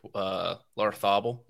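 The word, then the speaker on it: Larrazabal: I mean, the guy who won, the Spanish guy who won last week, Larthabel, is that how you pronounce his name? – That's correct, yeah.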